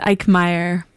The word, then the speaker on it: Eichmeier: I pronounce it Eichmeier, but the German Eichmeier is much appreciated.